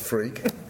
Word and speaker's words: Freke: And that's Freke is spelled F-R-E-K-E, yeah. – But it really is pronounced Freke.